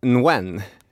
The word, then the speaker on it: Nguyen: Men jag tror att uttalet är så här, Nguyen. – Mm. Här tänker jag inte protestera. Nguyen, det låter ju bättre.